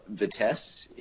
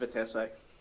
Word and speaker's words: Vitesse: In terms of becoming a youth development club, I'd draw a comparison to Vitesse in Netherlands, who are Chelsea's feeder club. – It's pronounced Vitesse.